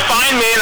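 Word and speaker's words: Feinman: I'm a fine man, as I tell my students, but it's not spelled F-I-N-E, it's F-E-I-N-M-A-N. Yes. – So did I spell it F-E... – You spelled it on Facebook F-I-E-N instead of F-E-I-N.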